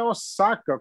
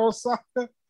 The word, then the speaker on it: Osaka: A Naomi é Osaka, como se tivesse dois S, mas se escreve com S só. vou fazer o quê? – Se ela fala que é Osaka, o nome dela é, é, é, é, é Naomi Osaka.